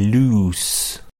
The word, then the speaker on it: loose: Et au niveau de la prononciation, to lose et loose.